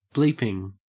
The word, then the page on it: bleeping: Australian English